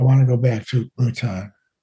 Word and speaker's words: Bhutan: Bhutan.